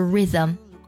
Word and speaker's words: rhythm: 另外呢，我们要特别的注意，当 H 在 R 后面的时候也是不发音的，比如说韵律这个单词读作 rhyme， rhyme 拼作 R-H-Y-M-E rhyme， rhyme。还有像 rhythm 跟这个单词也很像啊，它呢同样也是字母 H 在 R 后面，它读作 rhythm 拼作 R-H-Y-T-H-M rhythm。